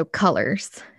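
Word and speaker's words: Cullors: I was like, I don't know how to say her last name. So Colors. Okay.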